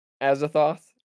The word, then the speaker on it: Azathoth: Yeah, I think this is definitely in contention with the Azathoth. I think that's how you pronounce it. – Yeah, you're right.